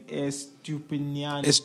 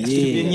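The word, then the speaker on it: Estupiñán: Estupinian. – I said it right. – Yeah. Estupinian.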